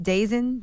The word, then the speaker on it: DAZN: D-A-Z-N, DAZN. Oh. I guess that's how you pronounce it.